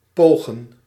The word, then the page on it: tenter: France; /tɑ̃.te/